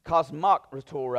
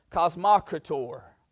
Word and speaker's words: Kosmokrator: Kosmokrator, I think, is how I looked up the pronunciation.